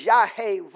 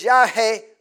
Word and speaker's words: Yahweh: The closest we can come to pronouncing his name is Yahweh Let me say it again. – The closest we can come to pronouncing his name is Yahweh Amen.